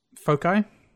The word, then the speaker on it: foci: Like, I made the, one of the how do you pronounce the plural of focus? – Foci? – Foci? Okay. That's what, I wasn't sure. – I think it's foci.